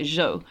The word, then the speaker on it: Zhou: And I probably mispronounced that, spelled Z-H-O-U, but sort of pronounced like Zhou.